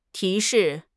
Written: 提示

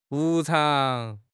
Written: 无偿

